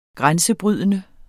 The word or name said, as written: grænsebrydende